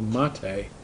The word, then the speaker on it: MATE: They have another one with a desktop that looks a lot like old XP, and that's called Mate. M-A-T-E, but it's pronounced Mate.